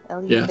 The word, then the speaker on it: Eleuthera: Eleuthera? – Eleuthera? Yes. – It's Did I say that right? Yay, me. – You said that right.